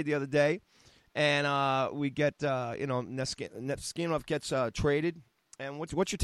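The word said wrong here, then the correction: Namestnikov: I butchered his name. I'm sorry about it. – Nemestikov. Thank you.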